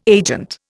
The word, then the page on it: agent: Indian